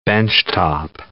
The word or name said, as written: benchtop